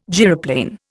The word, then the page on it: gyroplane: Indian